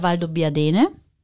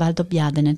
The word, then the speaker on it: Valdobbiadene: Weil viele sagen ja Valdobbiadene, aber es heißt Valdobbiadene.